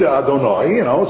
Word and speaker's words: Adonai: for the name is not pronounced the same way that it's written. And we pronounce it Aleph Dalad Nun Yud. So there are times in the Torah when Hashem's name appears Aleph Dalad Nun Yud. Or read Adonai, so that's the way to read it, because that's what the letters spell out.